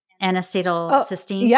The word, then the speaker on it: N-acetylcysteine: You mentioned, I'm probably going to say it wrong, N-acetylcysteine? – Oh, yeah, perfect.